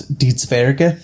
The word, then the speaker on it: Die Zwerge: it's die zwerge which is spelled die z w e r g e and that's for the dwarves that's translated in german yeah and it's based on a bunch of there are four novels that a German guy wrote, uh, Markus Heitz.